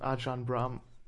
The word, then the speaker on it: Ajahn Brahm: kennen das Buch schon von Ajahn Brahm. Ich glaube, es wird so ein bisschen englisch ausgesprochen. Ajahn Brahm, was ein Mönch ist in einem thailändischen, ich glaube buddhistisch, in einem thailändischen Kloster, ein Brite, okay.